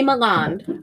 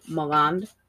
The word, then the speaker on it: Milland: The cast is Ray Milland. I think we say it wrong. I think Midwesterners say Milland. I think it's Milland.